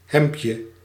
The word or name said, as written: hemdje